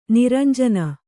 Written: ನೀರಂಜನಿ

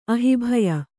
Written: ಅಹಿಭಯ

